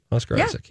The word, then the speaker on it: Oscar Isaac: Oscar Isaac. Am I saying his name right? Oscar Isaac. He's in it. – Yep.